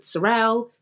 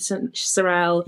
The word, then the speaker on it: Sarel: So I called myself Coco Sarel, and I would write Coco because I thought of what rhymes with Sarel. Chanel rhymes with Sarel. – Yeah. – Coco Chanel, Coco Sarel.